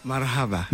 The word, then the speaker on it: Marahaba: On va parler notamment de Mahahaba Music Expo. Hein. Ça se déroule donc du côté euh, du Burundi. Est-ce que j'ai bien prononcé Oui, tu as bien prononcé. Mahahaba. Marhaba.